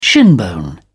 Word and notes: shinbone: British English

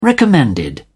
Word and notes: recommended: British English

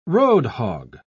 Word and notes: roadhog: British English

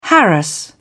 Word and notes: harass: British English

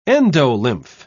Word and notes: endolymph: British English

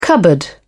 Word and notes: cupboard: British English

